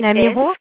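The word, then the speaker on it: Lamirault: C'est L'Amiron? S4... – Oui, l'Amiron. Je crois que c'est comme ça qu'on prononce. L-A-M-I-R-A-U-L-T. L'Amiron.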